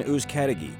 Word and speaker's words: Uzcategui: If you have a problem pronouncing the last name, it sounds like who got the key. Thank you so much. – Jonathan Uzcategui, candidate for Wilmington City Council, Thank you so much for your time.